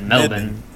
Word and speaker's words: Melbourne: That's how the Australians pronounce it. Melbourne.